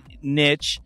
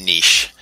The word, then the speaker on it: niche: It's been a specialty niche? – Yeah, either one. I think in French it's niche.